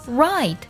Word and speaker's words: write: 第二组呢，就是 w r 开头，w 不发音，比如说 write 书写那个单词，还有 wrong 错误这个单词都是这样的。